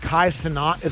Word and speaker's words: Kai Cenat: Like, I'll see these little clips of, like, there's a guy named Kai Cenat.